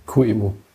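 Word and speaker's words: QEMU: Und ähm, der hat halt vor allen Dingen Chemo-Target, sprich, dann kann ich das mit Chemo sinnvoll testen. Ähm, Chemo ist nur ganz kurz. QEMO. Ach, QEMO. QEMO.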